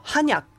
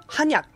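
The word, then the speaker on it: ハ ニャ (한약): 韓国語のちゃんとした発音で言うとハニャ、ハニャって言います。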